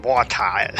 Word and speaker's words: Wartile: Es klingt ja schon so, Wartile. – So muss man es auch aussprechen, Wartile. – Wartile.